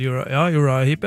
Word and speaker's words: Uriah Heep: Har du hørt om en gruppe som heter er det Uriah Heep? Uttaler jeg det riktig? – Ja, det vel Uriah Heep, ja.